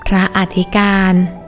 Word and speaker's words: พระอธิการ: พระอธิการ